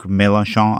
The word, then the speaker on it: Mélenchon: I mean, the populist left candidate, Jean-Luc Mélenchon, I don't know how to pronounce his name.